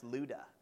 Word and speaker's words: Lydda: It says, he came down also to the saints who lived in, that's pronounced Lydda. Like ludicrous. No, not really.